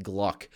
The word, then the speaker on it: GLVK: We're pronouncing it Gluck, the Gluck index. You pronounce the V as a U.